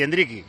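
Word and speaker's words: Endrick: Pero se ve ahí en esa acción precisamente hat-tricky y Endricky, que los brasileños a veces pronuncian así, el sonido K al final de la palabra.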